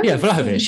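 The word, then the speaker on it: Vlahović: I mean, we're also being linked with Vlahovic out of. Is that his name? Vlahovic? Out of Fiorentina? Have I pronounced that right? Vlahovic. Yeah, right. Yeah, it's Dusan Vlahovic.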